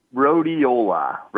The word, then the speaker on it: Rhodiola: You know, we're going to take another break here, and then we're going to talk about the other two, Rhodelia. Did I say that right? Rhodiola. – Rhodiola.